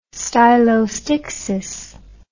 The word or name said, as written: stylostixis